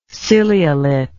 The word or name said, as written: ciliolate